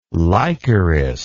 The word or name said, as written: lycoris